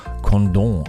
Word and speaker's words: Condom: Aber weil das in Frankreich liegt, wird es natürlich ganz edel Condom ausgesprochen.